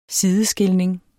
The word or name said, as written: sideskilning